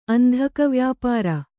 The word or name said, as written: ಅಂಧಕವ್ಯಾಪಾರ